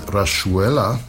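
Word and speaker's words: Rayuela: It was Gotan Project with, and that's how they pronounce it, Rashuela. That's the Argentinian pronunciation translated as hopscotch in the linked translation,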